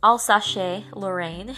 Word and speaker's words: Alsace-Lorraine: After the Franco-Prussian War, which is the war between France and Germany, or Prussia, we see the loss of Alsace-Lorraine. Alsace-Lorraine. That's how you pronounce it.